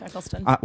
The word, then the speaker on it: Eccleston: I'm pronouncing his name right. – Yeah. That's correct, Eccleston.